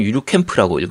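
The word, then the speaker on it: ゆるキャン△: 유루캠 버추얼 캠프 모토스 5편. 유루캠이 아니고 유루캠프라고 읽어야 됩니다.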